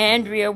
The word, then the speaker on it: Andria: If you would like to support our ministry financially, you may do so by sending your tax-deductible gift to Andrea Weeks Ministries, and that is spelled A-N-D-R-I-A, Weeks, W-E-E-K-E-S, Ministries, at P.O.